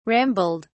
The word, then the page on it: rambled: British English